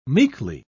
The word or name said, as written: meekly